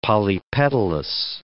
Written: polypetalous

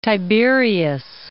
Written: Tiberias

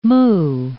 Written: moue

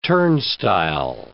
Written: turnstile

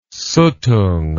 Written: Suttung

